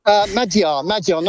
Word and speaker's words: Magyar: After weeks of talking to you, I can finally pronounce Magyar correctly. Are you proud of me? – Magyar, not quite. There's more a D than a G, I'm afraid.